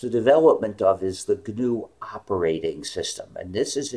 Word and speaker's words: GNU: Just that GNU is pronounced GNU. – GNU. Okay, my apologies. – Yes. We don't read it as an initialism, because it's more fun to call it GNU.